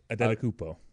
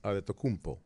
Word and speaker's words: Adetokounmpo: I Adetokounmpo. – Adetokounmpo.